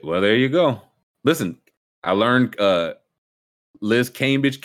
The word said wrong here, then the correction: Cambage: Cambage. Now I know it's it Cambage. – It's Liz Cambage.